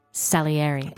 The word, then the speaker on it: Salieri: How's my pronunciation of Salieri? – Beautiful, yeah.